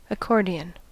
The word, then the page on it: accordion: American English